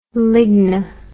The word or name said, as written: ligni